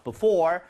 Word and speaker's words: before: Before, 아까 전에 뭐 어려운 단어는 아니에요. 근데 오에다 강세를 좀 주면서 발음해 주는 거좀 추천을 드리도록 하겠습니다.